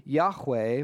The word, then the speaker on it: Yahweh: We pronounce the word Yahweh, but really, this is just the pronunciation more scholars think is best. We're not 100% sure. Ancient Hebrew has no vowels, so that's challenging.